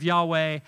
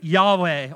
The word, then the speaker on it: Yahweh: And so when you combine the consonants of Yahweh and the vowels of Adonai, you get Yahavah. Okay? But most scholars think Yahweh or Yahweh was probably the best pronunciation.